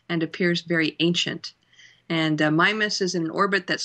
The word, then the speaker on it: Mimas: I tend to pronounce it Mimas. I think either one is correct. Either pronunciation is correct.